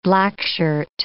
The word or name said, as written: Blackshirt